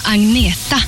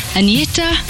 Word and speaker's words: Agnetha: Hello, I'm Agneta. I know it's very hard for you to pronounce, but you can say Agneta or you can say Anna.